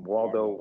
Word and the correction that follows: Wardlow: Wardlow versus Jungle Boy and Luchasaurus.